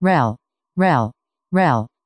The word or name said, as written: rhel